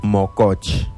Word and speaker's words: mococh: La lengua yocotán se pronuncia mococh.